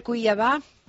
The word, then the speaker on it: Cuiabá: Pues estamos muy bien, ansiosos de conocer Cuiabá.